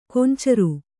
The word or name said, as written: ಕಾಂಚಾರ